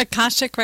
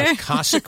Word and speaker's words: Akashic: Akashic record reader. – Akashic record reader.